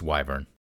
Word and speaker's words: wyvern: It says wyvern or wyvern is correct, but all the pronunciations that I found online, Google, English pronunciations, YouTube channel, and so forth, it's wyvern.